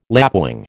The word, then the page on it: lapwing: American English